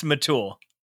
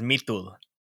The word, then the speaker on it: Mitul: It's Mitul. – No, that's not what you said. Come on. I wanted the cool pronunciation. – Yeah, the correct pronunciation is Mitul.